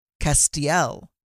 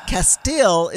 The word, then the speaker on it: Castiel: His name is Castiel. Castiel is his name. That is Castile is not his name, Scott.